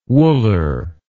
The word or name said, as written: wooler